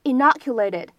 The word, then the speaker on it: inoculated: 那么再来看一下第二处发音技巧，这个 inoculated。